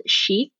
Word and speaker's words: Sheek: So I am actually in the process of creating an eco-friendly women's apparel line called Chic. It's spelled S-H-E-E-K.